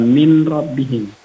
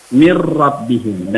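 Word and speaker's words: mir rabbihim (مِن رَّبِّهِم): Min robbihim, kalau tulisannya min robbihim. Maka cara bacanya bukan min robbihim, tapi langsung ditasydidkan. mir rabbihim, dan itu jangan didengungkan. Maksudnya kan mir rabbihim.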